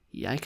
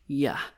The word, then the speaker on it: jeg: Jeg kan bare godt lide at hygge mig. Ah, at hygge mig. You know, mig often gets pronounced like ma. Jeg often gets pronounced like ja.